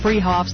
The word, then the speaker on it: Fruhoffs: Spelled Fruhoffs, pronounced Freehoffs.